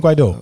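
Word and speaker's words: Guaidó: Guaido. – Guaido. toestand. – Guaido. – Guaido. Nee, Guido. Guido. Met de klemtoon op de O.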